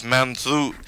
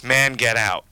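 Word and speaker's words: Mangetout: That was Wet Leg with, I believe the proper pronunciation of the French word is Manzout, but to me, because of the way the word is spelled in English, it's man get out.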